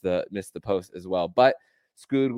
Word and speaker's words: Skog: Wilmer Skog was Skog, that's how you pronounce it, according to Doug Plagans on the broadcast.